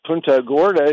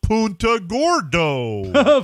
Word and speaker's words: Punta Gorda: Or as Jimmy Cerrito would pronounce it, Punta Gordo.